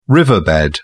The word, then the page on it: riverbed: British English